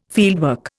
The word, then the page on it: fieldwork: Indian